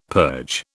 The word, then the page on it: purge: British English